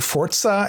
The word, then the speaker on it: Forza: But when I finished that, it was actually like 2 or 3 days after Forza Horizon, or I think Forza is how you're supposed to pronounce it.